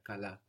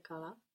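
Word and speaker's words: kala (καλά): Many English speakers, for example, native English speakers, might feel like they're hearing a G on kala. But it's actually a soft K, not as plosive, not as hard as we would say it in English. In English, we would say it like kala, no? But in Greek, it's softer, kala. But it's a K.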